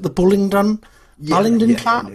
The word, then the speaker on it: Bullingdon Club: The Bullingdon? Club? Yeah, how do you say it? – The Bullingdon Club.